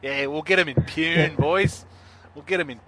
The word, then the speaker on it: Pune: So it's in Pune, or Pune? – Pune. Yeah. – Pune. There you go.